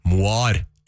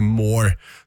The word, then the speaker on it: Moar: det riktige uttalelsen Nelly Moar, ikke Moore, som jeg har sagt utallige mange ganger, og jeg legger meg helt paddeflat.